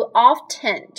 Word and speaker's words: often: 很明显，希尔多不喜欢吃这种香菜，或者是你是不是 pronounce the t in often？often 这个词 o f t e n，很多人有不同的读法，有的人习惯读 often，有的人喜欢读 often，就喜欢把中间的那个 t 这个音发出来。